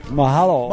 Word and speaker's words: Mahalo: I thank you for Maloha. Did I say it right? – Mahalo. – Mahalo. Okay, I just told him to go to take a jump in a lake or something in Hawaiian.